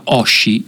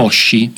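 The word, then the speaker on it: hoshi: Quindi per esempio la parola stella, che si pronuncia Oshi in giapponese, avete sentito che ho enfatizzato un po' l'H all'inizio, diciamo che l'ho aspirata, Oshi.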